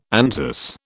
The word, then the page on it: Anzus: British English